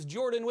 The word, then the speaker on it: Jordan: My name is Jordan with a silent PH in the middle, and I am joined always by my wonderful co-host, Sir Lucian, over at Sir Lucian Gaming.